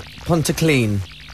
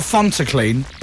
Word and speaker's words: Pontyclun: Pontyclun. – Pontyclun? – Pontyclun. – Yeah. Not Fontyclun. – Pont. – Right. – Says Fontyclun here. – Yeah. – I said Pont as in font.